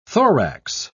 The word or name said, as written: thorax